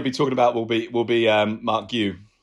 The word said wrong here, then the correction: Marc Guiu: Marc Guiu. Guiu. There's been lots of debate about how to say his name, basically because essentially because it's a Catalan name, but you actually say it the same as you would in Spanish.